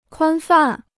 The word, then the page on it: 宽泛: kuān fàn